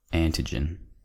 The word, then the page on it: antigen: American English